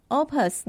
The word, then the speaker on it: Opus: 在乐谱上，你可以注意一下，它会用英文字母 O、 P，再加上一个小点点来表示，或者写 O、 P、 U、 S，这是拉丁语，念成 Opus。 Opus 的后方会有一个数字，就是这首乐曲的号码。例如，上周我们听的曲子是作品编号二十，在乐谱上就会写 O.P. 一点二十。那 Opus Number 到底是谁负责编号的啊？